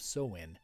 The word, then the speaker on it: Samhain: Now Halloween's origins date back to the ancient Celtic festival of Samhain. It's spelt different than it sounds. It's spelt like S-A-M-H-A-I-N, and the Celts,